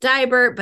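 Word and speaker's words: Deibert: You know, this is the thing when you've got, my last name is pronounced Dibert, but it's spelled E-I because of the German thing.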